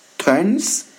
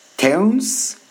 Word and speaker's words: Theuns: I think it's pronounced turns. Teuns, T-H-E-U-N-S.